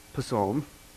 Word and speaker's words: Psalm: If you're hooked on phonics, how would you pronounce that? Psalm.